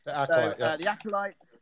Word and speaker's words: Acolyte: Moving very, very swiftly on to now The Acolyte, if I'm saying it correctly. – Yep. The Acolyte.